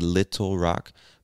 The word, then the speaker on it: Little Rock: You can say Little Rock, but I think many Americans will pronounce this as little, almost as if the two T's were two D's.